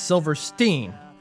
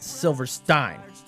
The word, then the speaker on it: Silverstein: Our band name is pronounced Silverstein, not Silverstein.